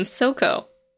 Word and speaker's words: Sokko: I always pronounced her name Sokko, like Southern Comfort.